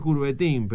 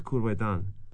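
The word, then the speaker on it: Coubertin: Se escribe Courbetin, pero creo que es Courbetan. Los que saben francés me rezongan.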